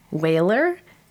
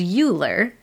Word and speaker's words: Euler: E-U-L-E-R. I have heard this pronounced Whaler and Euler, and your dad corrected me that it is Euler.